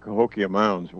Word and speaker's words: Cahokia Mounds: I assume they're talking about Cahokia Mounds. What do you think, Sam? – There it is. I couldn't pronounce it. I figured somebody would know the name. – Right. Yeah, I've been to Cahokia Mounds.